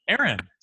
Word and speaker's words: Aaron: Aaron. Eric? What? No, Aaron.